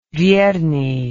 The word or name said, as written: Vyernyi